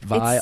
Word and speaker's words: Vial: It's just Vial. So, like this V-I and then emphasis on the all.